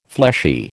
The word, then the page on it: fleshy: American English